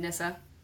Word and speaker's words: Nisse: But there are these little Scandinavian elves called Nissa, or like little Nissa men, and it's spelled N-I-S-S-E. – But pronounced like Nissa.